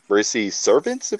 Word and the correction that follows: Mortal Savant: if I say his name right? – Mortal Savant. – Mortal Savant? – Yeah.